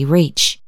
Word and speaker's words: Reach: Reach. Reach was touchy about the pronunciation of his name, claiming that it was properly Reac with two syllables and the accent on the last.